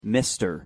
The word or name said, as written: mr